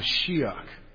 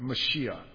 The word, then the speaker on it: Mashiach: Messiah is the translation of the original Old Testament Hebrew noun, Mashiach. That's how you would have pronounced Messiah in the Old Testament. Mashiach. Let's say that together. Mashiach.